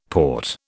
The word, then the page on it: Porte: British English